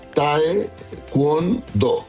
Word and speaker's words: taekwondo: No hay una I. Por lo general la gente le dice taekwondo. Uh-huh. Le mete una I entre medio. Uh-huh. Es A-E. Taekwondo. – ¿Y qué significa? – Taekwondo.